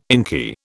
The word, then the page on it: inky: British English